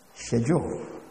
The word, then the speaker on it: schedule: That's the way the British man pronounces it, schedule. Well, in America here, this is the way it's pronounced, schedule.